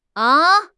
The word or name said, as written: a1